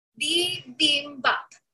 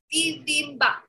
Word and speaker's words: bibimbap: Uh, can y'all help me say this word? The bibibap isn't too bad. How do I say that? Bibimbap? Bibimbap? I've always wanted to learn. Bibimbap. Bibimbap.